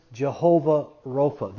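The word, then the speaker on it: Jehovah Rapha: That's not really the way you say it in the Hebrew. Nobody knows how to say it in the Hebrew. Some people say Yahweh, some say Jehovah. We say Jehovah.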